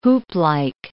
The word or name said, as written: hooplike